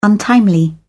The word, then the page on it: untimely: British English